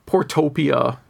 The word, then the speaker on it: Portopia: He says it's a lot like a Portopopia, which I mispronounced, or Shadowgate, point and click. Those are actually pretty different games. I tried to do the Portopia mystery game, just because I know it's like a hugely influential game in the genre.